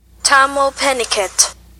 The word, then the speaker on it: Tahmoh Penikett: Tahmoh Penikett. – Oh, it picks it up really well. Say it again. – Tahmoh Penikett.